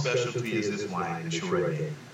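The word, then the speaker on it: Scheurebe: Chorepe is spelled Cheurebe. And it's pronounced Chorepe, which will probably scare a lot of people off. But it's not that bad. Chorepe. That's how you pronounce it.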